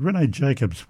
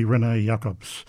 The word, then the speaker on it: René Jacobs: The baritone soloist singing as the Don was Johannes Weiser, and I mispronounced this name before. I said René Jacobs was the conductor. Well, he's Belgian, so it will be René Jacobs, conducting the Freiburg Baroque Orchestra.